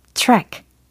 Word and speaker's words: track: 트랙이 아니고 track. tr, tr, tr, tr. 이 자음이 두 개가 뭉쳐져 있죠. tr, tr. 그 다음, ack 부분은 ek 이렇게. 그래서 track, track, track 하고 계시죠?